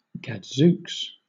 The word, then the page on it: gadzooks: British English